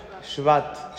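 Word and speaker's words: Shvat (שבט): Shvat.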